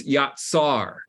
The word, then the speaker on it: yatsar: Phonetically, it's yatsar.